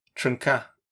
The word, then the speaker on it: Trnka: Trnka. No, no fucking like vowel between the R and the N.